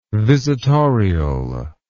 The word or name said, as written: visitorial